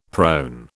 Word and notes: prone: British English

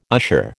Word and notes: usher: American English

